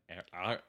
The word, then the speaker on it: Ehrenreich: Because sources that are close to the project, and I mean real close, Mr. Alden Ehrenreich.